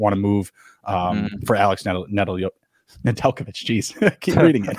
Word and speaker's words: Nedeljkovic: I always want to pronounce out his name, how it's written, Nedeljkovic, but it's Nedeljkovic.